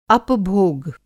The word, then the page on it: अपभोग: Hindi